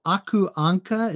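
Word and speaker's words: Aku Ankka: Donald Duck magazine is Aku Anka. Is that how you say it? – Oh yes, yeah.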